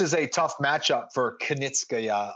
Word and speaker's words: Kunitskaya: And I'll give you props for pronouncing Kunitskaya because a lot of the Americans respect to you, but they want to go Kunitskaya. They always want to go Kunitskaya.